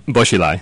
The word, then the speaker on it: Bo Xilai: Would you say the scandal involving Bo Lijai, or I'm mispronouncing the name? – Bo Xilai.